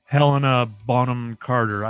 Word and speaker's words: Helena Bonham Carter: Tim Burton, she was kind of like the Hella Bonham Carter at the time, if you shall, because now all he uses Helena Bonham Carter.